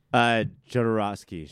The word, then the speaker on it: Jodorowsky: Jodorowsky? – Jodorowsky.